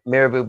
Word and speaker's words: Mirabeau: Mirabeau B.